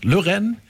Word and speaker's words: Lauren: Lauren, das ist natürlich dann an die französische Aussprache von Lauren gemeint, heißt ihr Künstlername.